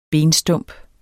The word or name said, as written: benstump